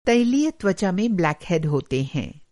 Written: ब्लैकहैड

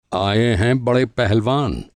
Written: पहलवान

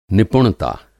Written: निपुणता

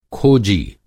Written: खोजी

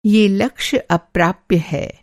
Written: अप्राप्य

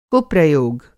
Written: कुप्रयोग

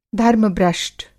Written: धर्म-भ्रष्ट

